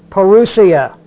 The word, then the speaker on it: parousia: The word that's used for coming is the word parousia. P-A-R-O-U-S-I-A. Parousia, if you put the emphasis on the right syllable.